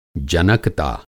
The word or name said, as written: जनकता